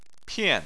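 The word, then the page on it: pian4: tone 4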